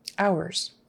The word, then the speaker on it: hours: O-U-R is hour, like O-W-E-R, kind of, hour. And the H is silent. Don't forget, no H. Don't say visiting hours, visiting hours. And that final S again is pronounced as a Z.